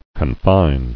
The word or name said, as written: Confiner